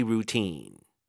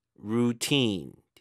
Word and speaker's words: routine: 그다음에 you can spice up your daily routine. Routine 뒤쪽에 주고 routine.